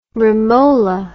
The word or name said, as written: romola